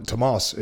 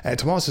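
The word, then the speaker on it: Tomás: There was part Swedish pronunciation, part Irish pronunciation, man. Tomas, it should be. Tomas is a Gaelgor from County Kerry in Ireland.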